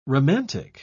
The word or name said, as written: romantic